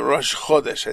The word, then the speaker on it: Rosh Chodesh: The Rosh Chodesh, I think the accent's on the first syllable there.